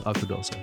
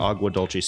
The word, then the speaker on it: Agua Dulce: No, it's Agua Dulce. – Agua Dulce. So, yeah, so you've got to use the Spanish there. Agua Dulce. Spell that for us. – A-Q-U-A. – I think it's G. A-G-U-A-D-U-L-C-E.